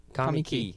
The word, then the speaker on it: Kamiki: Uh, was it Kamika? – Kamiki. – Kamiki. – Kamiki. Kamiki. Kamiki. Ah, K A M I K I.